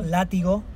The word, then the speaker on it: látigo: Látigo. – Látigo? – Yes. – With the lions and everything? – Yes. – Yeah, okay. Látigo.